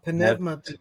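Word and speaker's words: pneumati (πνεύματι): Yeah, pneumati. That's right. Not pneumati or pneumati, but pneumati.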